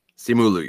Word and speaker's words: Simu Liu: Now, correct me if I'm saying this name wrong, but it's Simu Liu, right? – Simu Liu, yeah.